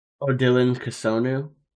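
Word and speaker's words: Odilon Kossounou: According to a pronunciation website that we found, I believe it's Odilon Kasonu.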